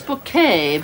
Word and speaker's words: Bucket: It's bouquet because... – Oh, I'm sorry, there, Hyacinth. – B-U-C-K-E-T, bouquet. The accent on the second syllable.